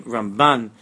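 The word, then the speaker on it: Ramban (רמבן): The Ramban, we emphasize the last two letters, that's the left side of the word. Because in Pnimius, the Ramban is Bina. Bina is the left side. So we pronounce the name Ramban.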